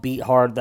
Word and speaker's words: Beathard: I apologize for those of you who reached out, said that it's CJ Beathard. I do know that. Just his name is spelt Beat Hard. That's just how I've always said it. I should have clarified. I know his name is said Beathard.